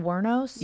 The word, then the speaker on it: Wuornos: How do I pronounce her last name? Eileen Wuornos? – Yeah. – Is that how you say it? – Mm-hmm.